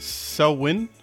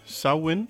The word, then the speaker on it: Samhain: It's pronounced Selwyn. Selwyn. – How the fuck is that pronounced, Selwyn? – Yeah, it looks, for the folks at home, it looks like Samhain. Mm-hmm. But it's pronounced Selwyn or Selwyn.